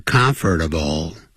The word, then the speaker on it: comfortable: 不要忘了这个 comfortable，实际念的时候是念成 comfortable，三个音节，而不是念成四个音节。